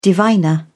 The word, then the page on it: diviner: British English